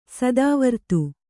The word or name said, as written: ಸ್ಥಾವರತೆ